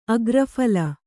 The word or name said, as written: ಅಗ್ರಫಲ